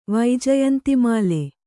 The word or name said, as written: ವೈಜಯಂತಿಮಾಲೆ